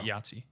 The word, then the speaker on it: IATSE: Yahtzee, that's their union name. – Really? – Yeah. – That's so. – IATSE. They pronounce it Yahtzee.